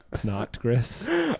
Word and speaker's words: Pinot Gris: It's pronounced Pinot. Pinot Gris.